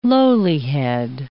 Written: lowlihead

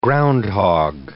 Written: groundhog

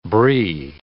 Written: Brie

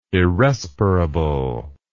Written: irrespirable

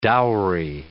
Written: dowry

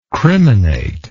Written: criminate

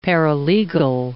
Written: paralegal

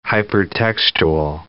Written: hypertextual

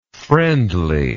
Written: friendliness

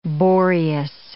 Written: Boreas

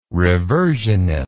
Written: reversionist

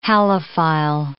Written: halophile

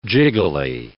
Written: jiggly

